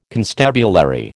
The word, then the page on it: constabulary: American English